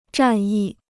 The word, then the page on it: 战役: zhàn yì